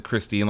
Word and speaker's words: Khrystyne: but the reason I said Christine like that was because it's spelled Khrystyne.